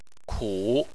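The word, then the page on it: ku: tone 3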